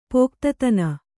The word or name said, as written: ಪೋಕ್ತತನ